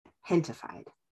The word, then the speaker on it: Gentefied: So, but I want to talk about the TV show Hentified. And that's spelled G-E-N-T-E-F-I-E-D. And okay. It's a play on the word gente, which is for people in Spanish, and gentrified.